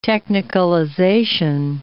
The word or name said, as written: technicalization